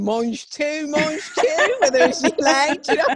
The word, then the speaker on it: gilet: No, she said, it's a hard G, it's gilet.